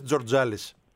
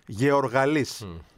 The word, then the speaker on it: Γεωργαλής: Τι μου λες, Τζορτζάλης? Εκεί θα πεις, αδελφέ, Γιάννης, ούτε Τζιάννης. Γιάννης Γεωργαλής.